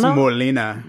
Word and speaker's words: Molina: And this is a segment Malana? Where Brian tries to Malana? – It's Molina. – That's a hard. I can't even say it after you. Say it again. – Molina.